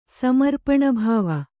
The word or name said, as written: ಸಮರ್ಪಣಭಾವ